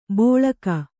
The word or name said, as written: ಬೋಳಕ